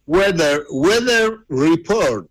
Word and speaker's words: Weather Report: ¿Cómo se pronuncia? Weather Report, ¿no? – Weather Report. – Weather Report. – Guarda, ¿eh? – Sí, ninguno de los... Oh, sí. – Vos tenés inglés. – Qué bien que lo pronuncia.